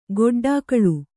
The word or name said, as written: ಗೊಡ್ಡಾಕಳು